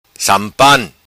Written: Sampan

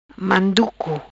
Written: Manduco

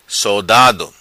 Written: Sodado

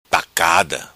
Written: Tacada

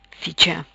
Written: Ficha

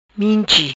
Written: Minchi

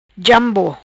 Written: Jambo

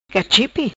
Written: Cachipe

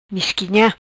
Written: Misquinha

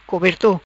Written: Coberto

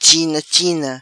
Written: China china

